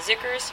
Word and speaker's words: Xikar: There's a brand out there called Zicker, and it's spelled X-I-K-A-R.